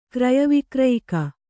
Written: ಕ್ರಯವಿಕ್ರಯಿಕ

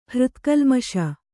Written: ಹೃತ್ಕಲ್ಮಷ

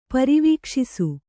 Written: ಪರಿವೀಕ್ಷಿಸು

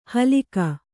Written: ಹಲ್ಕ

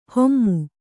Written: ಹೊಮ್ಮು

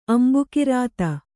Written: ಅಂಬೂಕೃತ

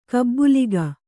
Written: ಗುಬಲಕ್ಕಿ

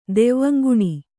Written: ದೆವ್ವಂಗುಣಿ

